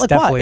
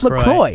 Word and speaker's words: LaCroix: Nice job saying it correctly. So many people say LaCroix. It is not LaCroix. It's LaCroix. – Yeah. – It's LaCroix.